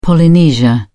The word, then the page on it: polynesia: British English